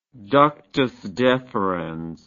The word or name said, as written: ductus deferens